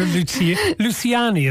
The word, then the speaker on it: Luciani: Clara Luciani, is that how it's pronounced? – Yeah, it's obviously an Italian name, but she pronounces it in a French way.